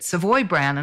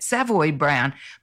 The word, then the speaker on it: Savoy Brown: And I apologise because here in Australia, we've always pronounced it Savoy Brown, and I hear you pronouncing it Savoy Brown. But I guess that's just the difference in where we are in the world, right? – Right, right.